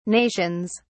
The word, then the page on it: nations: British English